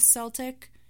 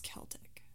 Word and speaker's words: Celtic: guess I guess the early pronunciation um, like in its origin, is Celtic, but the modern standard is Celtic.